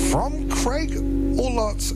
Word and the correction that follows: Ellett: and I've been saying it wrong, Craig Ellett's latest album, and that was Parlor Game, and that was just awesome.